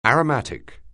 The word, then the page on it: aromatic: anglais